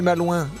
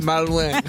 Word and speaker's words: malouin: Il a pas dit je suis pas loin, il a mal prononcé. – Je suis malouin.